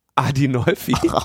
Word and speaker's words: Adinolfi: Adolfini und Spinelli von der GUE, also die Linke in Deutschland. – Ich glaube, du hast zwei Namen falsch ausgesprochen. Das ist nicht Adolfini, sondern Adinolfi.